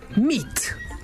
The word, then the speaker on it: meat: A primeira é meat. Isso aí, meat com a grafia M-E-A-T significa carne, enquanto se escrevermos M-E-E-T significa conhecer. Então, meat pode significar carne ou conhecer. Depois temos a palavra for. For, escrito F-O-U-R, significa quatro, enquanto que F-O-R significa para alguma coisa, como em This is for you.